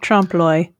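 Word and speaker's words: trompe-l'œil: Trompe l'oeil. – Trompe l'oeil.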